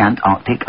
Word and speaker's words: Antarctic: The program host really won my heart with his correct pronunciation of Antarctic. There is a C after the R.